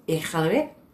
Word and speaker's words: Iqaluit: Can you pronounce for me what the capital is? Because I can't say that word. – Where? Iqaluit. – That's how you say that? – Yeah. It's the best that I can do.